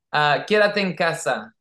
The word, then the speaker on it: Quédate en casa: Quédate en casa.